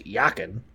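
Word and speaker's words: Jachin: Hyakin, pronounced Hyakin, stands for he will establish, and Boaz means in God is its strength.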